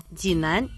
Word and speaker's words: 济南: Ди читается в третьем тоне. Это древнее название реки, которая протекает по территории города. Нань читается во втором тоне, означает юг. Динань, то есть на южном берегу реки.